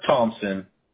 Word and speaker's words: Thompson: Arthur Thompson.